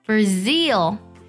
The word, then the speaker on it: Brazil: 巴西呢，它的重音在第二个音节上， Brazil。